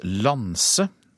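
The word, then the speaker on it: Lance: Mange takk skal du ha, Eivind Molde, som altså er om bord på eh, forskningsskipet som eh, altså uttales Lanse, forstår jeg, selv om det skrives med C.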